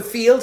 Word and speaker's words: Feeld: I'm on field, yeah. And you think, how have I never heard of this? – Yeah, it's spelled F-E-E-L-D.